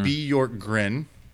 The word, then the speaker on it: Bjorkgren: What you said to me is that the J is silent, and then it's mm-hmm. Bjorkgren.